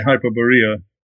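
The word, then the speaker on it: Hyperborea: So let's get into the juicy stuff, which to me is Hyberborea, or I guess you pronounce it Hyberborea. – Yes, I would say Hyberborea.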